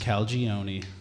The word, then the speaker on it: Calagione: Calagione.